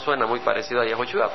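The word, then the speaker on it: Yehoshua (יהושע): El nombre Josué es de descendencia hebrea y en el verdadero hebreo se pronuncia Yehoshua y en inglés es Yahshua, Yahshua, Yahshua, Joshua.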